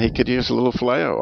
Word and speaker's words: phileo: Today, we're talking phileo. Am I saying that right, Al? – You're saying it right.